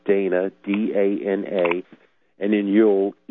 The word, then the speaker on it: Ewell: Now, have you ever heard of a man named Dana Yule, except it's spelled like the holiday. Like it's not. It's pronounced like the holiday, like Y U L E, but it's really spelled E W E L L.